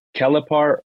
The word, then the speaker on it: Kelepar: Is it Kelepar or is it Kelpar? Is it E silent? How do you pronounce it? – No, your first is good, Kelepar. That's Kelepar. How we say it.